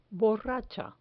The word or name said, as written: borracha